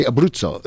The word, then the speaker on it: Abruzzo: And he is, and it's Ray Abruzzo. Did I say it right? – You said it perfectly.